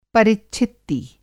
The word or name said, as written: परिच्छित्ति